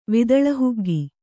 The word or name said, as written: ವಿದಳಹುಗ್ಗಿ